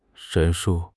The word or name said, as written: 陈述